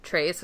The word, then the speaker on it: Tres: He's at Trace, which is spelled T-R-E-S, write stuff.